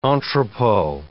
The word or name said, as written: entrepot